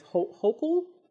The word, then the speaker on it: Hochul: I think her name is Hochul. That's how you say her name.